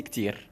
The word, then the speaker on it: ktir: Et sans oublier de prononcer le R en R, merci Ktir.